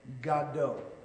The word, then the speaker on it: Godot: It wasn't until years later that I learned that those two clownish figures weren't waiting for Godot, but for Godot, which is, of course, the proper pronunciation of Samuel Beckett's famous absurdist play, Waiting for Godot.